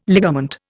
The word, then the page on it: ligament: Indian